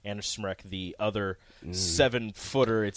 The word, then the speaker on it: Smrek: Anna Smrek. Tough name to pronounce. – Smrek. – Smrek. Anyway, S-M-R-E-K.